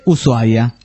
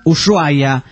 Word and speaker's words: Ushuaia: Dice, como en castellano la H suele ser muda, debería decirse Ushuaia. Pero muchos mamones le dicen Ushuaia. Y los peores son los gringos, que le dicen Ushuaia. – Ushuaia.